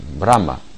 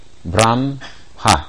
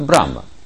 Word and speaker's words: Брахма: Брахма. Брамха, так говорят. Брахма или Брамха произносится. Или еще Рамха. Если вы откроете русский, там написано Рамха. Или Рамхат. Это поклонение Всевышнему называется. Но это Брахма.